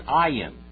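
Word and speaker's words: Ayin (עין): And then we have the word Ayin. Ayin. Ayin. And that is silent also.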